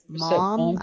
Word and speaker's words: Maugham: Mom.